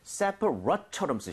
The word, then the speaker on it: separate: separate처럼 쓰셔야 돼요. so 아. – rate이 아니라 rut.